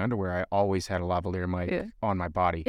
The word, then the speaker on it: lavalier: How do you pronounce it? Lavalier? – It's lavalier